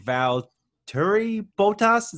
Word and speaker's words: Valtteri Bottas: So I'm going Valtteri Bottas.